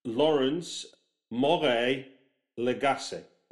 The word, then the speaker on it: Laurence Morais-Lagace: And today I'm talking with Laurence Morais-Lagace.